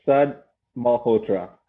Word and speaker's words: Sud Malhotra: So, Sud Malhotra.